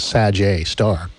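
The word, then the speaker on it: Sgr A*: Astronomers found something unexpected near our massive black hole, which is known as Sagittarius A, or pronounced the Sag A star. It's a Sag A.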